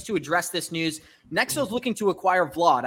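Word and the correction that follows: Vauld: I know I pronounced that incorrectly. So please, Johnny, why don't you pronounce it correctly for me and give me your thoughts? – So I think it's Vault.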